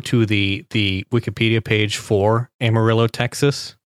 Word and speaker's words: Amarillo: I wonder, you know, given this logo, it's almost as if they're trying to say, don't pronounce the two L's, so it's Amarillo.